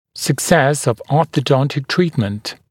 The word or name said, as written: success of orthodontic treatment